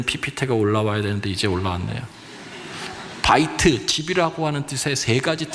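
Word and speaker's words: 바이트 (בית): 여기 집이라고 하는 것은 히브리어로 바이트 이렇게 발음하는데 여기에는 세 가지 뜻이 있어요.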